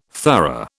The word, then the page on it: thorough: British English